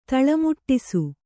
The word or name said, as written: ತಲೆಮೆಟ್ಟಿಸು